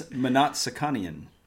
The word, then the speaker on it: Mnatsakanian: It's Mnatsakanian.